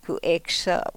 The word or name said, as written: ku ekso